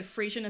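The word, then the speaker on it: Friesian: No, Frisian as in cow. – Oh, okay. – That's how you pronounce that, isn't it? Yes, yeah.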